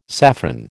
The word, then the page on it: saffron: American English